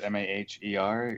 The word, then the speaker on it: Maher: At Mike Mayer. Last name is Maher. Ignore the H when saying it, but when you spell it, it has to be there.